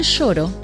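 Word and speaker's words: choro: El shoro, que se escribe con C al comienzo y se pronuncia sh, es un estilo de música brasileña muy antigua que se desarrolló en Río de Janeiro en 1870.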